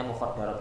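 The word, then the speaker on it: mukhaddarat (مخدرات): Bacanya mukhaddarat, kalau mukhaddirat nanti bahaya, Pak.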